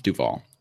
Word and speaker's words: Duval: So I just found the real quick the NBA pronunciation guide from the draft. It's Trevon Duval.